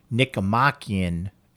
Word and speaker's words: Nicomachean: I'm now saying Nicomachean ethics. – That's the correct way.